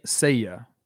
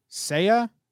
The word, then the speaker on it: Seiya: How do you pronounce his first name? Saya? – Seiya. – Seiya? – Yeah.